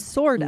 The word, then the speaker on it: sorta: And just a quick pronunciation note, oftentimes native speakers will say kinda and sorta. – Mm, good point. Right? Right? We rarely yeah. will emphasize that F at the end.